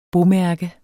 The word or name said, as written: bomærke